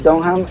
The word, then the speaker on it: Dunham: A los Jeff Dunham, por cierto, las personas que no han visto a Jeff Dunham, ahí la H no es muda. Se escribe D-U-N-H-A-M, ese es el apellido del ventríloco.